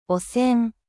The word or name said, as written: 汚染